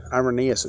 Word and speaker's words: Irenaeus: And so this quote by Saint Irenaeus, Irenaeus is probably how, closer to how you say it.